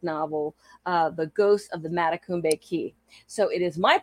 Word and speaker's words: Matacumbe: And you pronounced Matacumbe Key properly. – So glad. – A lot of people stumble on that one.